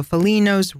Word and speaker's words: Foligno: All right, let's go south of Spello to a town called Foligno. F-O-L-I-G-N-O. Don't forget, you don't pronounce the G's in the middle of a word. So Foligno.